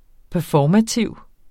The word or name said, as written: performativ